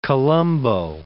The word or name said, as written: Colombo